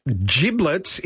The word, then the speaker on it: giblets: Giblets is pronounced with a J, not a G.